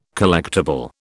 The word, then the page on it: collectible: British English